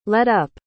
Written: let-up

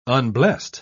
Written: unblessed

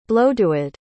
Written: blodeuwedd

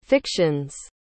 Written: fiction's

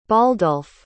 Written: baldulf

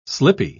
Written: slippy

